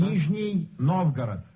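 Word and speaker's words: Nizhny Novgorod (Нижний Новгород): Nizhny Novgorod.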